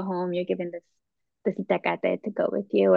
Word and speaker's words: Itacate: It's Itacate. It comes from the Nahuatl itacatl, and that's the, the language of the Aztecs,